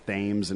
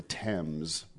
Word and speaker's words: Thames: Yeah, but we call it, it's pronounced Thames in Connecticut.